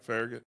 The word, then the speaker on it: Farragut: We saying that right, Farragut? – Man, that's right.